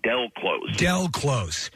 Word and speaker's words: Del Close: A gentleman named Dale Close. – That's from the UK Vice interview. They say his name wrong. Del Close. Yes.